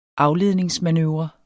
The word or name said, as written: afledningsmanøvre